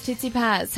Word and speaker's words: Tsitsipas: Stefanos Tsitsipas has defeated Jannik Sinner. – Didn't start with an S, started with a T.